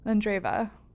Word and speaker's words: Andreeva: Andreeva.